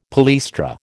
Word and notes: palaestra: American English

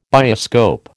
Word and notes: bioscope: American English

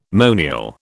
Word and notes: monial: British English

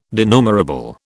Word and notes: denumerable: British English

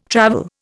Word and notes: travel: Indian

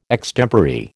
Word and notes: extempore: American English

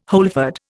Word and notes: wholefood: Indian

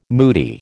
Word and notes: moody: American English

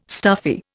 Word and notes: stuffy: Indian

